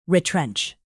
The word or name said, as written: retrench